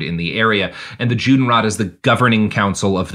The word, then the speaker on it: Judenrat: There's an organization called the Judenrat, which is rot is like it's spelled rat, like the animal, but it's like a governing council, right?